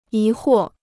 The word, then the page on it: 疑惑: yí huò